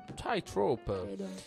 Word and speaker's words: Tightrope: Tightrope.